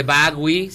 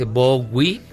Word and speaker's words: Bowie: Escuchando a David Bowie, porque no se dice Bowie, se dice Bowie.